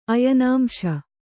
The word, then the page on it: ಅಯನಾಂಶ: ayanāmśa